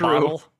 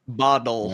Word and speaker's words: bottle: Because us English people can pronounce T's. – Oh, that's mainly it. And Americans just go duh all the time. – It's not a bottle with a D.